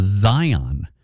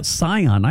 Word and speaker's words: Scion: For the car, Scion, my husband calls it a Zion. Said it drives her nuts. She's not even from Lancaster, and it drives her nuts when people say it wrong, when they say Lancaster. She hopes we have a terrific Taco Tuesday. Yeah, Scion. I call them Scions. That's how you're supposed to say it, right? – I think.